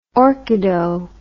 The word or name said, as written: orchido